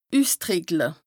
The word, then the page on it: üstrìckle: Bas-Rhin; Strasbourg